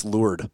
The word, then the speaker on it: Lourdes: So, we tweeted out a now prospect, Emma Schmitz, in Rochester Lourdes win over, apparently it's Lourdes. Apparently you don't pronounce the S.